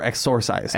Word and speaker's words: exorcised: Somebody is not able to be exercised. – Like exercised or exorcised? – Exorcised. I pronounced it correctly.